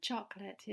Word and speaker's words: chocolate: The word chocolate is typically pronounced as two syllables, not three. Okay? Chocolate. Chocolate. Okay? The second syllable is not pronounced late, but lit. Lit.